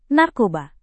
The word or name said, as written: narkoba